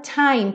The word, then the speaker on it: time: Preste atenção também nesse final, a palavra time, perceba que não tem time. Ele alonga bastante o time e fecha na letra M. Time. Termina com o lábio fechado.